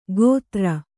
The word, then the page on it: ಗೋಧರ: gōdhara